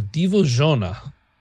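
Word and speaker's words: Dziwożona: So moving on to um female swamp demons in Slavic mythology the Boginka or Divozhona, which I did, in fact, Google how to pronounce that.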